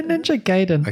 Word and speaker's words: Ninja Gaiden: Yeah, so Ninja Gaiden, is that how you pronounce it? – Yeah, Ninja Gaiden.